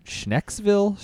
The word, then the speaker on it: Schnecksville: I'm going to butcher Schnecksville, Schneckiesville. – Schwanksville? – No, it's not Schwanksville. It's called like Schneckiesville.